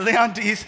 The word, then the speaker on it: Leontes: Leonies, is that how you say it? Leon, hey, oh, hi, Caitlin. Yeah, Leontes.